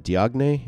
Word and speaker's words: Diagne: And in fact, is it Diagne? Is that how you pronounce it? – Oh, I knew this was going to come up, and I was trying. It's not that. – All right. – But I was trying to remember at it. It's like, it might be Diagne, something like that. They're not gonna pronounce the G.